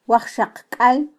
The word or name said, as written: wajxaqkal